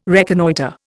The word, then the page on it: reconnoitre: Indian